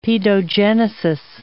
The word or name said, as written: paedogenesis